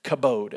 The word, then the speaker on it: kabod: According to the Bible, in the Old Testament, the word for honor is a Hebrew word pronounced kabod. Everybody say kabod. It's kind of like, yeah, the kabod. The commode, but it's not the commode, it's the kabod. K-A-B-O-D, kabod, or kabod, however you want to pronounce it.